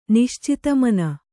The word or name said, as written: ನಿಶ್ಚಿತಮನ